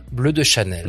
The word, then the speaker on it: Bleu de Chanel: Is it Bleu de Chanel? – Bleu. Bleu de Chanel. – Bleu de Chanel. Yes. – You have to say it with the French pretension.